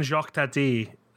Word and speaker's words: Jacques Tati: Other than Jacques Tati... That's how you pronounce that.